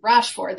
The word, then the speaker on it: Rochefort: I think it was Rochefort, I believe. – Rochefort, because it sounds like the car Ford.